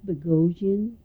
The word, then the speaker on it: Bogosian: My name is Mary Boghossian, B-O-G-O-S-I-A-N, Carson, C-A-R-S-O-N. – And your maiden name is? – And my maiden name is Boghossian.